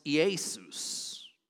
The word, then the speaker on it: Iesus: But in the Greek, this is pronounced Iesus.